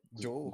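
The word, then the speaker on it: Zhou: Tem o Zu, que se pronuncia. Como é que é? Joe. Gu, Joe, Joe.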